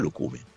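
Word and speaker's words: Lucumí: Durante la presentazione video social Juve ha pronunciato il suo cognome senza accento. Vedi, allora se lo dice lui è Lucumi.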